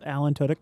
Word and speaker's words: Alan Tudyk: I like what I can't pronounce this guy's name, Alan Tudyk. – Yep, Alan Tudyk.